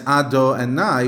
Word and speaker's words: Adonai: On the other hand, we're pronouncing it as Adonai, which is God's a ruler, God's a king.